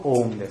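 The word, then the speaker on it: オ ウ ム (ओम्): で、それを、それから、とても基本的な音節、それ、サンスクリットだけではなく、世界のどこでも、これいろいろ言葉の基本的な発音の、この音節はオウムです。なぜなら、あうま。一番最初、喉から出ます。それから、口の中に出る、唇から出ます。それみんな合わせて、三つの発音の場所。喉、口、それから唇。それ合わせて、あうま、あうま。そして、オウムになっています。それから、何言いました？そのオウムのみんなシンバル、例えば音のシンバル。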